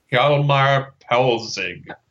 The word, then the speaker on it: Hjalmar Poelzig: Hjalmar Poelzig.